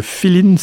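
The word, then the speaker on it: filin: Le Feeling, ça s'écrit F-I-L-I-N, c'est un style musical cubain qui est un petit peu voilà un petit peu sur le mode balade amoureuse, etc.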